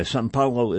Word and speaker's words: São Paulo: And it reads, by the way, São Paulo is the pronunciation of Sao Paulo, the way it is printed in English, S-A-O-P-A-U-L-O.